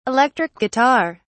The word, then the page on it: electric guitar: British English